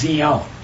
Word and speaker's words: Zion: The correct pronunciation is Zion. Like Z and the zebra. – We hear Zion. – Yeah, when everybody says Zion, it's not, it's Zion, according to the Hebrew.